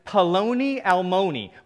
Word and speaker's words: Paloni Almoni: The Hebrew here is Paloni Almoni, which rhymes with baloney.